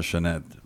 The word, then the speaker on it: Sinéad: Y cuando tuve que pronunciar Sinéad O'Connor, yo me acordaba que cuando ella apareció, como yo fui a un colegio irlandés, se escribe Sinéad, Sinéad, pero se pronuncia Sinéad.